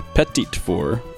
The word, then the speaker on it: petit four: How do you say that? – This is the second time you've asked me one of these questions. A petit four. Petit four.